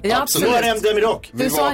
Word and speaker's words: Muharrem Demirok: Muharrem Demirok. – Så heter han ja, mycket riktigt. – Man uttalar inte H såklart. – Nej. – Nej, du sa Murhamem.